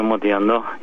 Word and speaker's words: Modiano: Inaczej się akcentuje po włosku. – No więc właśnie, ale Francuzi mówią Modiano i tak. Tego się trzymajmy.